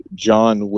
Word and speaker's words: Jon: My Instagram is John Will Morrow Jr. I spell it the right way, J-O-N.